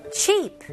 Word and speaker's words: cheap: 이거는 우리나라 발음으로 그냥 cheap 이렇게 좀 짧게 하는 경향이 있어요. 근데 영어 모음들은 살짝 길다고 생각하시면 더 좋을 것 같아요. 그래서 이거는 그냥 cheap보다는 cheap. cheap.